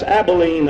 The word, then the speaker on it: Abilene: Now that's Abilene. The last time I looked at the map, that's Abilene. And here's a fella doesn't know how to pronounce Abilene,